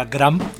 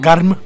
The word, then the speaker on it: Garm: Aka Gram. – Gram. – Garm.